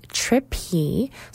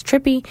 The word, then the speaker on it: TRP.P: So the band is Trippy, so TRP.P, and then it phonetically says Trippy, and their album is called Mirror Soul, and they give that retro feel.